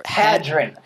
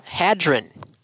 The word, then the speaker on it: Hadron: How do you pronounce the large... – Hadron. – Hadron. The large Hadron Collider. – The R and the D in hard-on. – Yeah, Hadron. H-A-D-R-O-N. – Yes, not H-A-R-D-O-N.